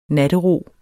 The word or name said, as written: nattero